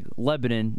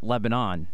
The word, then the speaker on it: Lebanon: Just like Lebanon, not Lebanon, right next door to Lancaster.